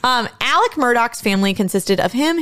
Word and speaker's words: Alex: The father's name is Alec, not Alex, it is spelled. – But it's spelled with an X. – It is spelled Alex Murdaugh. It is pronounced Alec Murdaugh. – That's a completely different name. You can't just change your name. – I don't. – The Murdaugh one I kind of get, but Alex, like with an X and you pronounce it Alec, is wild.